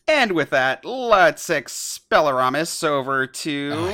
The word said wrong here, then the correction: Expelliarmus: He still said it wrong. – Expelliarmus. – For all of y'all who don't know, which is everybody except us, our producer Meg had to remind how to say Expelliarmus before this. And was like, I know how to say it, and then said it wrong anyway.